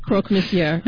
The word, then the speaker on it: croque monsieur: I had my espresso martini, my secondhand smoke, and a croque monsieur.